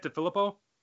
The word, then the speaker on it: DeFilippo: For me, John DeFilippo, am I pronouncing his name right, DeFilippo? – That's how I pronounce it.